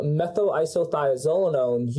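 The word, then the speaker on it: methylisothiazolinone: So methyl isothiazolinone. – Great job. That's perfect. As good as I've heard it pronounced by anybody else.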